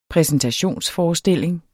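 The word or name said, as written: præsentationsforestilling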